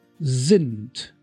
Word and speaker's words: sind: S at the beginning of a word and is followed by a vowel is pronounced as the in zeal. Like z, they, z, sind, they are, sind.